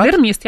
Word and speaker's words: таксидермист: Таксидермист, я правильно сказала. – Да, таксидермист, это правильно.